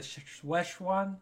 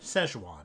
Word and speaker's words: Szechuan: Yeah, so you showed me this Pickle Rick montage on YouTube, which is very cringe-inducing, but basically they wanted the Szechuan sauce. I don't know if I said that right. – Szechuan.